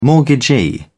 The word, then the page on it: mortgagee: British English